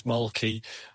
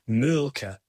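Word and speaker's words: Mölkky: We use Molky. Most of the people we play against use Molky, but the Finns have a very unusual pronunciation, which, please forgive me, I'll try it. Mulka. So I think that's, something a bit more like that. But to us, it's Molky.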